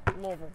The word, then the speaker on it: Louisville: Like Louisville. Like pretend you have like marbles in your mouth. – Yeah. – Louisville.